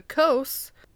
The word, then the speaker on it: Causses: So, the coast, which is spelled C-A-U-S-S-E-S. So, it's like coast without a T.